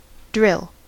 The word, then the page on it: drill: American English